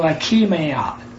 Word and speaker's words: Achaemenid: And that is spelled A-C-H-A-C-M-E-N-I-D. And so I had to get up on phonics, and they pronounced it. And it's pronounced like A hyphen K-E hyphen M-A hyphen N-A-D. So Achaemenid Empire.